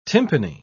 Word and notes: tympani: British English

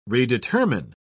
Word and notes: redetermine: British English

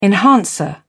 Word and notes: enhancer: British English

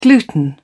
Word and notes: gluten: British English

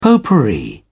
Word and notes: potpourri: British English

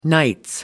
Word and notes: nights: American English

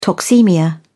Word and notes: toxaemia: British English

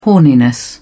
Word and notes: horniness: British English

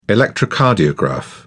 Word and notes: electrocardiograph: British English

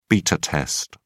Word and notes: beta test: British English

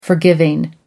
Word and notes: forgiving: American English

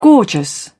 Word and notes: gorgeous: British English

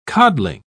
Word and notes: codling: British English

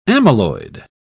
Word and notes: amyloid: British English

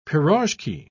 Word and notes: pirozhki: British English

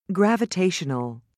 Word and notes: gravitational: British English

